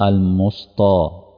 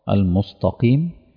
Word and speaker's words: المستقيم: المستى، ماشي، هتلاقي في ناس بتقول على التاء طاء، تعرفوا حد بيقرأ كده؟ المستى المستى المستقيم، في ناس بينطقوها كده، فأنا متوقع إن ده الأخطاء اللي ممكن تحدث، فتوقع الكلمات اللي غامضة.